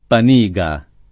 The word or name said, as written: Paniga